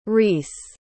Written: rhys

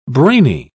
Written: brainy

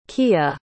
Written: keyah

